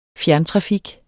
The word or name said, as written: fjerntrafik